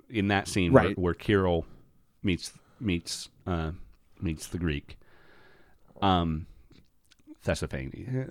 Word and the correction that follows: Theophanes: Theophanes is his name. Actually, I keep trying to put an S in the first syllable for some reason, but Theophanes.